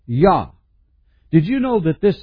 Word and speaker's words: Yah: His name is Jah, or Yah, as it's pronounced.